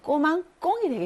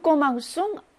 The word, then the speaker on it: commençons: 이것도 역시, se 다음에 o가 나오면 끄 발음이 나기 때문에 꼬망꽁이 되겠죠. 그래서 얘는 꼬망꽁이 되는 것을 방지하기 위해서 이 se에다가 우리가 세디유를 붙이죠. 그래서 Nous commençons à apprendre le coréen 이렇게 해야지 맞는 문장이 되겠습니다.